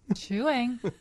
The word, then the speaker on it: Chewang: Chewang. Sounds like Chewing, but Chewang. Spelled like chewing.